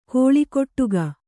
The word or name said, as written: ಕಳ್ಗುಡಿಕ